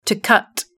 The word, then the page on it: to cut: British English